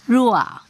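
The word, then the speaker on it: ruah (רוח): 那这个灵啊，在希伯来的原文呢是怎么念的？ruah。 – 那当然呢，这个词除了解作灵，也可以解作风和气息了。那 ruah 在以赛亚书的第一次出现的时候呢，就跟审判有关的。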